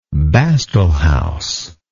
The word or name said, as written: bastel house